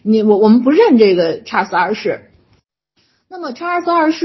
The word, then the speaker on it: 查尔斯二世: 那么当时的英格兰议会呢，一看苏格兰直接任命这查理，我这块也还是按照我的发音啊，就是查尔斯二世啊，就是在国内翻成查理二世，但是实际上按照英文发音应该是查尔斯二世啊，我习惯了说成查尔斯二世，就宣布查尔斯二世为这个不列颠和爱尔兰的国王。